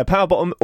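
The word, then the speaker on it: PWR BTTM: Yeah, Power Bottom, all capital letters, no... all the, there's P-W-R-B-T-T-M. – Right. – That's how they spell it.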